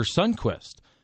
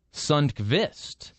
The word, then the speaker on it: Sundqvist: Like I got in a little argument at work once about Oscar Sundquist because he's called Oscar Sundquist. But I actually went to the pronunciation, and I do believe it should be Sundqvist. But I think it's the Americanized version of Sundqvist.